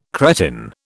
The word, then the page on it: cretin: British English